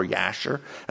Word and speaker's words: Jasher: Verses 12 and 13 are actually a quotation from an extra biblical source called the book of Jasher or Yasher, as some people pronounce it.